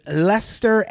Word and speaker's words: Leicester: And in 73, he started working with Lester Advertiser. I like that I pronounce Lester right when it's spelled L-E-I-C-E-S-T-E-R.